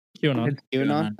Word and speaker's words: QAnon: But, And there's also, how do you say QAnon, or is it... – QAnon. – Okay, I was making sure I was saying that right.